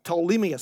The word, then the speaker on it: Ptolemais: When they had completed the voyage from Tyre, we reached Ptolemais. So that's a silent P.